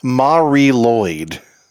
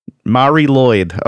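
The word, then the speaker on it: Mari Lwyd: So I looked it up and it says the pronunciation is actually Mary Lewyd. – Mary Lewyd. Okay. Well, that sounds actually more Welsh.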